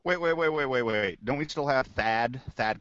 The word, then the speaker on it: Thad: Thad. – Well, if you got to have the drawl too.